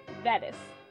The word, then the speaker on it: Vetus: I'm overemphasizing the T. Vetus. Rhymes with lettuce.